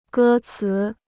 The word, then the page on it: 歌词: gē cí